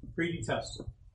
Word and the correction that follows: predetermined: Predetermined, I said that wrong.